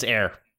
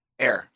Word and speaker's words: Ayer: Is it David Ayer? – I think it's Ayer. – Ayer. Oh, there you go. Okay. He says, I would like to see the David Ayer cut, if only to see a movie more in line with the Comic-Con trailer, which I think was his original version.